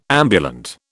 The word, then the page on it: ambulant: British English